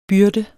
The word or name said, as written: byrde